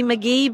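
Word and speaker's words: McGhee: It's D McGee, but the H is silent.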